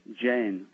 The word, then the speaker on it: Jain: No, just Jain.